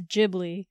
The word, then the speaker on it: Ghibli: She had been doing it a long time, and she said it's Ghibli with a J. – Ghibli. – She even said, I know you Americans pronounce it Ghibli, but in a Japan, Ghibli. We say Ghibli.